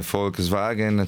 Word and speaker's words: Volkswagen: Ancora criticano la mia pronuncia di Volkswagen, avete ragione, Volkswagen, ce l'ho fatta.